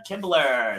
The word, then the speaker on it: Kibler: So helping me with this discussion is Celia Kimbler. Did I pronounce that right? – You did.